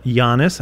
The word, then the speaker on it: Giannis: But Giannis, I think, is the correct pronunciation.